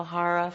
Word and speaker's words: hara: The head for perception, the heart for emotions, the hara for spiritual strength and stability.